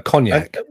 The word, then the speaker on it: Cognac: Jiznak. – Cognac, I think it's pronounced.